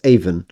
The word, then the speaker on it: Avon: It is, try and say it, it's Avon. It's almost as if the O isn't there. It's Avon. – Stratford-on-Avon. – Try it. – Avon.